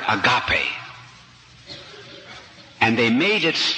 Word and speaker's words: agape: A-G-A-P-E. Agape. Sometimes pronounced agape, but it's agape.